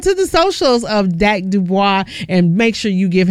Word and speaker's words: Dubois: And let me tell y'all, it's funny because I didn't know how to pronounce his last name. Well, he got a whole song out here telling us how to pronounce it. It's called It's Pronounced Dubois.